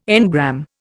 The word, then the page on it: engram: Indian